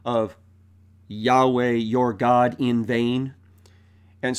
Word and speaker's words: Yahweh: And in Hebrew of that time, that was pronounced something like Yahweh. It was four letters in the written language, represented in our English as YHWH. And the Hebrew pronunciation does seem to go as Yahweh.